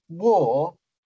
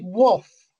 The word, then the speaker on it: Waugh: a guy called David War, spelled like that, W-A-U-G-H. But he actually liked to be called David Woff, I was told.